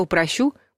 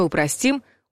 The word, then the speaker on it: упростить: Если в слове «принудить» ударение нужно ставить на корень, здесь все наоборот. Ударение падает на последний слог. «Я упрощу», «мы упростим», «он упростил». То же самое и о глаголе «облегчить».